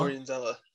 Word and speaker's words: Florian Zeller: Florence, what's his name? Zeller. – Florian. Florian Zeller. – Florian Zeller.